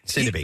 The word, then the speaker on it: Sidibe: Sidibe.